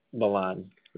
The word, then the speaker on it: Milan: And one quick question is my teammate's name is spelled similar, but it's pronounced Milan. Is your name pronounced Milan or Milan? – Milan.